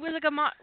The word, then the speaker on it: Wizengamot: He's saying Wizengamot. He's doing the G sound. He's doing the G sound, the G.